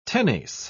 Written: tenace

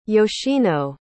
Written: yoshino

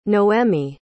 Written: noemie

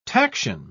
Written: taction

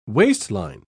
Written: waistline

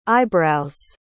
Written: eyebrows

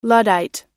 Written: luddite

